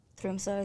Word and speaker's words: Tromsø: But I've, you know, been everywhere there, whether it's Oslo or to the top to Tromsø is how you say it.